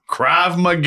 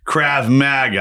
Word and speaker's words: Krav Maga: Krav Maga. Yeah. Yes. Krav Maga.